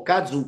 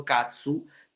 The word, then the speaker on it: kazoo: Si scrive K-A-Z-O-O Kazu, però io dico Katsu per, insomma, per fare l'ironia, e quindi faccio l'ironia del Katsu, quindi un po' capito, è un po' tutto.